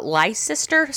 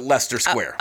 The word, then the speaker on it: Leicester: He met him while drinking in a pub near Leicester Square. – I think they actually pronounce it Leicester, but Leicester. L-E-I-C-E-S-T-E-R, but I believe they pronounce it Leicester Square.